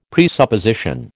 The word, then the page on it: presupposition: American English